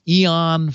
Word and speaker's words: Aeon: He has a site called Eon 14, spelled with an A.